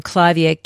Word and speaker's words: Clivia: The name Clivia can be pronounced as the name Clive to rhyme with hive, or with a hard sound as in liver, Clivia. Many of the Clivia enthusiasts pronounce it the latter way. So basically, they're both right.